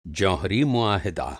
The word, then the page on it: مُعاہدہ: मुआहिदा